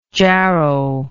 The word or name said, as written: jarrow